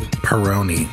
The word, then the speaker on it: Peroni: Peroni.